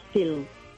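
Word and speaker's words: Stihl: porque como estabais así tan con lo de la pronunciación de Steel, los de las desbrozadoras, es que resulta que es una compañía alemana. Entonces, se pronuncia Stil, y eso, dado por saco, básicamente.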